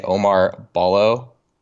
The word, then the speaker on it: Ballo: His name is, hopefully I don't botch this, I'm going to say Omar Ballo.